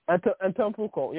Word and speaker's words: Antetokounmpo: I can't say the last name either. Antetokounmpo. Yeah. – Antetokounmpo. Yeah, I think that sounds about right.